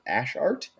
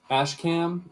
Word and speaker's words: Ash Cam: Like any sports athlete that you can think of, we got a custom artwork promotion going with, is it Ash Art? Ash Art, am I saying that right? – Ash Cam. I don't know what his full name is, but his Instagram name is like Ashcam_art.